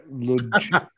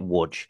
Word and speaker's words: Łódź: Łódź, right, is spelled L-O-D-Z. – Yeah. Ludge. – And trust me, when we were over there in Poland, the amount of times everyone butchered that name, but you pronounce it Wudge.